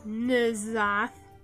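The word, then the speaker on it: N'Zoth: Very little is certain about Nuzanth, pronounced Nuzanth, Nuzanth.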